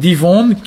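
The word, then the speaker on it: difonn: Alors, ça s'écrit Difonde, mais ici, le F est presque toujours prononcé V. Donc, Divonde, qui est lent, en fait.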